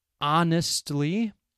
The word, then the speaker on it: honestly: Honestly, H-O-N-E-S-T-L-Y, honestly. Our, H-O-U-R, the H is silent. Honestly, our. Some words that start with the letter H have a silent H, so you do not pronounce it. There is honestly and our. What else? Herbs, H-E-R-B. An American pronunciation. Honor, H-O-N-O-R.